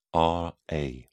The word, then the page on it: ra: British English